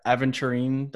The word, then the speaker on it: Aventurine: It's so not the sparkly one is called the Aventurine dial. I don't know if I'm saying it right. – Yeah, you are.